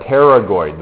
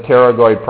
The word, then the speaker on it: pterygoid: And they are called the pterygoid. The P is silent, just like in pneumonia, right? So these are the pterygoid processes.